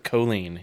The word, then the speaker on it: Colleen: She pronounces it Colleen, yeah.